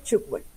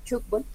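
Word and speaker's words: tchoukball: Bueno, primero entonces se pronuncia chútbol. – Ah, vea pues. – Bien. Cuando tengas la oportunidad, el balón cuando rebota contra una malla, contra un marco, hace ese sonido como ch. Entonces por eso se pronuncia y se denomina así, chútbol.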